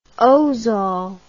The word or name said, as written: ozal